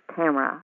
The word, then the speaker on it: Tamora: T-A-M-O-R-A. But it's not pronounced Tamora, it's pronounced Tamara.